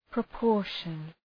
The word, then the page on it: proportion: prə’pɔ:rʃən